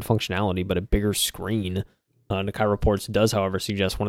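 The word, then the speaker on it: Nikkei: It is pronounced Nakai, and it is the stock market in Japan.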